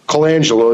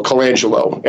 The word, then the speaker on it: Colangelo: His name is Matt Colangelo, is I think how I pronounce his last name. It's C-O-L-A-N-G-E-L-O, Colangelo.